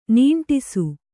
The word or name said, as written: ನಾಂಟಿಸು